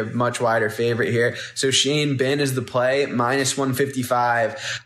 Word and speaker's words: Xie Bin: If you're looking to bet that, your sports book, it's actually spelled XIE space BIN. So Shane Bin is his name, but that's how it's spelled, XIE BIN, -155.